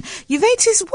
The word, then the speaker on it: uveitis: Uveitis, what is that? – Good. The way we pronounce it is uveitis.